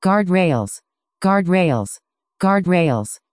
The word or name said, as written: guardrails